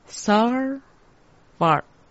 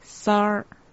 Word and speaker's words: Sarva: 第八句，sar va la ba ye。第一个字 sa 念成 sar，白字旁一个帆，念成发。